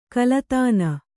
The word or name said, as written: ಕಲತಾನ